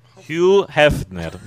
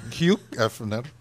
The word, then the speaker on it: Hugh Hefner: Uf Hefner. – Hugh Hefner. Hugh Hefner.